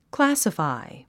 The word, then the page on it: classify: klǽsəfài; クラァサファイ